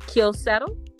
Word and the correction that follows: Keela Settle: is that what I'm saying? Mm-hmm. Right? Yeah, Keela Settle.